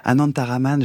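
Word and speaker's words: Anantharaman: Non, c'est Anantaraman. – Anantaraman, je l'ai mal prononcé tout à l'heure. Ah, excusez-moi. Je m'excuse auprès des auditeurs. J'ai dit Ananta. J'ai mal prononcé votre nom.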